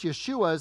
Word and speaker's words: Yeshua: Now, of course, Yeshua is how you pronounce Jesus, but you're also going to hear how we pronounce some of the names of kings.